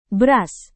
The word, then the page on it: beras: indonesian